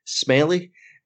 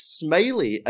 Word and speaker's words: Smellie: and their surname is called is spelt S-M-E-L-L-I-E, Smelly. But they all go around saying, no, it's Smiley, and everyone's like, yeah.